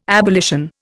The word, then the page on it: abolition: Indian